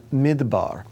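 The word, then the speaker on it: midbar (מדבר): which is the word midbar. And that word is spelled mem dalet bet resh. But that same spelling can also be pronounced midaber, which means to speak.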